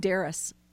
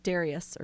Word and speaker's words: Darius: But that's historically, well, and that's interesting too, because King Darius, or Darius, or Darush, depending on how you say it.